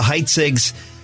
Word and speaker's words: Heitzig: Okay, folks, we're going to be right back with the Heitzigs. It's spelled E-H-E-I-T-Z-I-G.